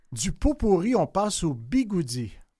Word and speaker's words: bigode: Un mot apparu au 19e siècle qui nous vient du portugais bigode, B-I-G-O-D-E, mais en fait, le portugais, c'est pas facile à parler, le portugais. Ça se prononce bigoudi, justement.